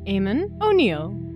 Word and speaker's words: Eamon O'Neill: This is the After the Story Wicked Library interview, and today we're talking with Eamon O'Neill, and I hope I said that correctly.